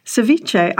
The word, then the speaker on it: ceviche: I mean, I thought it was ceviche with a V, but they've got it as ceviche with a B. Is that right? – Well, Spanish, they don't pronounce the V.